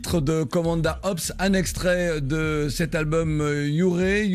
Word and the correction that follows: Hure: je ne sais plus si je prononce bien. – Je pense qu'on dit Ouré, d'accord. – H-U-R-E.